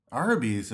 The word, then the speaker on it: Arby's: Is it, you mean an Arby's? Arby's? – The Orbeez. – I thought John Barleycorn helped. – How do you say it? – Arby's, of course.